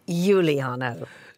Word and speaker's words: Uliano: I mean, there's two different ways you can say it, and you chose one of them. – What's the other way? – Uliano.